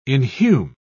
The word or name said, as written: inhume